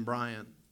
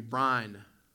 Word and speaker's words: Brian: H-E-P-H-E-R, Heifer. But as I mentioned, two syllables of my name, Brian. My grandfather, though, when he called my name, called it with one very long syllable. My grandfather called me Brine, B-R-I-N-E, I think is how you would word that. Now, it took about 5 minutes to say it. He drug that word out, but it was just one syllable, Brine.